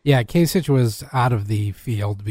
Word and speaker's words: Kasich: Kasich. I always say it wrong. It's Kasich. – I believe it is Kasich, yes.